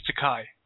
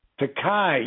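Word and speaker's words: Takei: I believe it's Takai. – Takai, yes.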